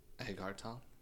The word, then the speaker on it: Agartala: A-G-A-R-T-A-L-A? Agartala? Agartala? – Agartala? – Agartala.